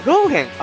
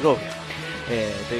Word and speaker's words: Raufen: インターネット上で頑張っているアマチュアミュージシャン、インディーズミュージシャンを紹介して早 5年半、今週ご紹介するアーティストは、ローフェン、 R-A-U-F-E-N と書いてローフェン、というアーティストのサラサという曲を聴いていただきました。